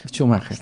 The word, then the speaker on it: Schumacher: No sé pronunciar su apellido, Perla Schumacher. Schumacher. Sí. Ok, muy bien. Sí, Schumacher.